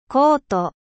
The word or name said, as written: manteau